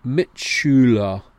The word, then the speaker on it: Mitschüler: Mitschüler, Mitschülerin. Some students have difficulty with the U sound.